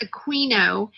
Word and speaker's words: Aquino: A book called Mind Wars, written by Michael Aquino, pronounced Aquino,